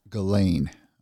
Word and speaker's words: Ghislaine: So how do you say her name? Giselle? No. – Ghislaine Maxwell? – Ghislaine. I wanted to call her Giselle Maxwell.